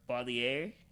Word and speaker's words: Baudelaire: but Sir Baudelaire.